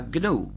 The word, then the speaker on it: GNU: Se escribe GNU, en inglés se pronuncia GNU, pero en castellano se pronuncia GNU.